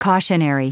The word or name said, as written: cautionary